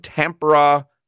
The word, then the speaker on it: Tampere: He is in Tampere, Finland. Did I pronounce that correctly, Doug? – You did well with that one. How's it going, guys? – Very good. Tampere, Finland.